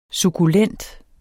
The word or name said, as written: sukkulent